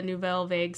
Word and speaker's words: Nouvelle Vague: Is it Nouvelle Vague? Vogue? Nouvelle Vague. – Well, it's French. Nouvelle Vague is the French pronunciation.